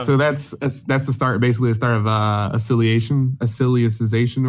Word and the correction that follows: Alicization: or whatever it's called. – Alicization. Yeah, I think I said it right that time.